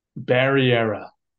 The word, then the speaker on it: Barrera: Barrera. – Barrera. Barry, like the show on HBO, era, like a time in history, Barry era.